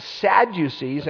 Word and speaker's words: Sadducees: And then you had the Sadducees, and you just pronounce it sad, you see, that pronounces it well and even defines what it is.